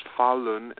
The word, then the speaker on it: Falun: It's pronounced Falun and spelled F-A-L-U-N Falun,